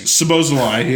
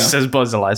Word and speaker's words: Szoboszlai: Szoboszlai, yeah. – Szoboszlai.